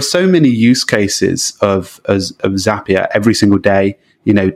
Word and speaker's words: Zapier: Zapier makes you happier is what we say internally. That's sort of the official pronunciation.